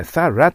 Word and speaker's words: thorough: これはね、 thorough と発音するような感じがいいです。 thorough と書いてあるけども、 thorough と発音するのは普通です。